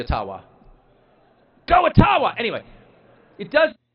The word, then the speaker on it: Ottawa: It cannot be taken from you, no matter who is in Ottawa, or as we say, Ottawa, which, by the way, is the correct pronunciation, according to my friends in the Ojibwa community.